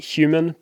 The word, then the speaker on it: HVMN: They pronounce it human, but it's with a V instead of the U.